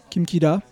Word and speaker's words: Kim Ki-duk: Kim Kida, ça se prononce en, coréen.